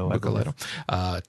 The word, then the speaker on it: Buccellato: What you were saying about the quite obvious seam, if you will, from when Buccellato, how would we say that? Whatever. – Buccellato. – Took over.